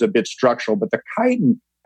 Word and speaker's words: chitin: What is chitin? – Chitin. – Okay. – Chitin, yeah, and it's pronounced like chitin. It's C-H-I-T-I-N, but it's called chitin.